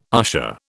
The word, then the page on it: usher: British English